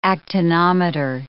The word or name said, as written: actinometer